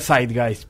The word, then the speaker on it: Zeitgeist: ¿Zeitgeist? – La pronunciación adecuada sería Zeitgeist, pero para todos los fines prácticos le decimos Zeitgeist.